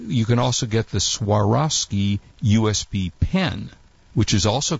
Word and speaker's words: Swarovski: It's uh, Swarovski. Swarovski. Is it? Am I pronouncing that correctly? Swarovski. – Swarovski yes.